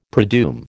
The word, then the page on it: predoom: American English